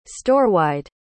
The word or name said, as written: storewide